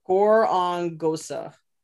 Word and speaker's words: Gorongosa: How do you say that name? – Gore. Gorongosa.